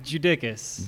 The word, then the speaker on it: Judickas: Judickas. – Judickas. Okay. So, like, not Ludacris, not Jadakiss, but Judickas? – You got it, man.